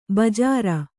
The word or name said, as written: ಬಜ್ಜರ